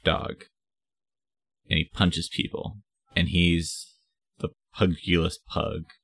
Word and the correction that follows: pugilist: I think it's pronounced like pugilist.